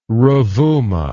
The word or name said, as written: ruvuma